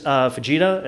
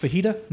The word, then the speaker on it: Vegeta: Yeah, he went as Vegeta, and I went as Goku. – No, is that pronounced fajita? No? – No, it is not.